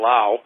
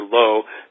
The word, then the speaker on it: Lao: But it's also worth mentioning that there is a Medusa in The Seven Faces of Dr. Lao, or Dr. Lo, depending upon which way you want to pronounce it, depending upon who says it in the film.